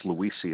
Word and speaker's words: Luisi: Last name is, it's Luisi. It's spelled L-U-I-S-I.